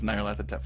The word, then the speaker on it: Nyarlathotep: Since Hotep is the Egyptian word, you should probably, if you're being all pedantic about it, say Nyarlathotep, because it's not a sound, right? – Yeah. I think we just settled it here just now. – Yeah. – we'll all pronounce it as Nyarlathotep from now on.